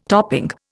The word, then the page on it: topping: Indian